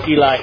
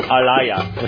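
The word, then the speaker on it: ilai (אלי): Note that ilai is pronounced ilai and not alaya, which is true. Note the final character, yod, is pronounced e, not ya.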